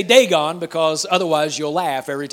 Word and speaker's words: Dagon: And it says that they took the Ark of God and took it into the house of, we pronounce it, Dagon. Now, I will probably continue to pronounce it Dagon, even though that's not how it's pronounced in the Hebrew. In the Hebrew, it's pronounced Dagon. Google it when you get home, or just Google it.